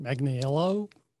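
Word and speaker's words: Manganiello: Magniello.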